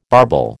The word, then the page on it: barbel: American English